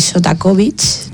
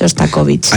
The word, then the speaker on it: Shostakovich: Que es la sinfonía número 10 de Shostakovich, ¿no? Si no lo he pronunciado mal, si lo he pronunciado fatal, no es Shostakovich. – Ahí estamos. – Muy bien, ahora sí.